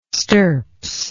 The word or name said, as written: stirps